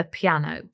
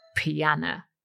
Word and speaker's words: piano: It is, of course, the piano, or piano, which rhymes with Joanna.